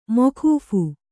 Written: ಮೋಕೂಫ್

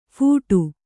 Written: ಫೋಡ್

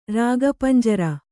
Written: ರಾಗಪಂಜರ

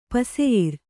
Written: ಪಸೆಯಿರ್

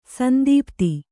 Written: ಸಂತಪ್ತ

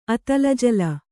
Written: ಅತಲಜಲ

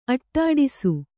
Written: ಅಡ್ಡೈಸು

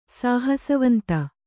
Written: ಸಾಹಸವಂತ

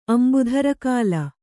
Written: ಅಂಬುಧರಕಾಲ

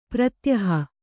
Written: ಪ್ರತ್ಯೂಹ

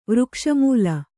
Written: ವೃಕ್ಷಾಮ್ಲ

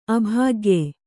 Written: ಅಭಾಗ್ಯ

